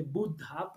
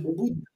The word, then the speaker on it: Buddha: Por eso el, eh, Buda, o para decirlo correctamente Buddha, para ser más concretos en su pronunciación, porque si no estaremos diciendo viejo y no ah, despierto. Oh. Entonces, Buddha.